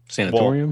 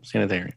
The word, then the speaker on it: sanatorium: Sanatorium? Well, sanitarium. – Actually, no. Sanitarium is actually a mispronunciation and a misspelling of sanatorium. They're the same thing.